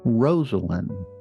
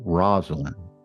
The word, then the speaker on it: Rosalynn: And let's make doubly sure we all know the correct pronunciation of her first name, Rosalynn, not Rosalynn. Almost like it was two names rolled into one, Rosa, which was her grandmother's name, and Lynn.